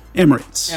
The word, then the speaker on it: Emirates: But uh, when you do, maybe you'll fly Emirates. Is that how you say it? – Emirates. Emirates. – Emirates.